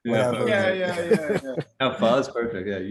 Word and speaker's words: pho: And I find that wonderful that you're giving them an opportunity to try it, because like am I pronouncing, is it pho or is it kind of like koi? Is it different? Like, oh, that was perfect. – No, yeah, it's actually pho.